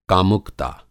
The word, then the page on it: कामुकता: Hindi